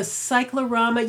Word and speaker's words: cyclorama: And they had the cyclorama, is that how you pronounce it? – The cyclorama, yeah.